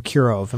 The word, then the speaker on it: Kirov: um, which are set off, I think, the worst aspects in 1934 when there's a murder of a man named Kirov.